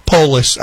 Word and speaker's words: Polis: Polis, I should probably say. Because if you pronounce it like police, he'd probably get upset.